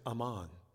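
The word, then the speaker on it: Amman: In fact, they call their capital city by their ancient name, although they pronounce it Ammon, and it is the capital of Jordan.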